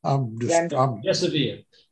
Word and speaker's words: remdesivir: Remdesivir.